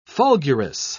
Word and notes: fulgurous: British English

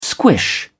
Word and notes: squish: British English

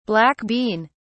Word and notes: black bean(s): British English

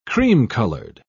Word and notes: cream-colored: British English

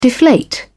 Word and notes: deflate: British English

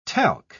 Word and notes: talc: British English

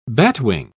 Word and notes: batwing: British English

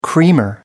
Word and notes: creamer: American English

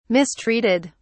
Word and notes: mistreated: British English

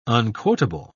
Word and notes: unquotable: British English

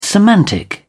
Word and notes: semantic: British English

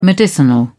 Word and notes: medicinal: British English

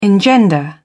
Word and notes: engender: British English

